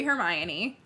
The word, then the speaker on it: Hermione: Which I guess everyone pronounced it like that in their heads pre-movie or pre-audiobook, but it's clearly Hermione. Like, that's a name that was already in place before Harry Potter.